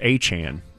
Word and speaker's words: Etienne: He said in the offseason that his name is actually pronounced Achan.